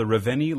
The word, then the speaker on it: Reveni: So it's called the Reveni Labs light meter.